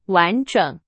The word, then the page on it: 完整: Chinese